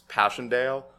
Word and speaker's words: Passchendaele: It's Passchendaele.